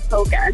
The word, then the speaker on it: polka: Do you pronounce the L? – No, I do not. I call it polka.